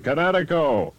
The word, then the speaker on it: Kinetico: Son, you should have remembered kinetic as in kinetic energy with an O on the end. Kinetico. – Kinetico. Spell it K-I-N-E-T-I-C-O.